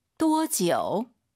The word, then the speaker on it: 多久: 多久. – 또 지우 아니고 아이유 사이에 오 넣어서 또 지우.